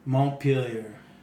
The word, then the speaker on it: Montpelier: How do you say that? Montpelier? – Montpelier.